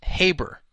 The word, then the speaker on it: Haber: It's Haber. It says rhymes with neighbor. Hmm. Well, I guess I said it wrong.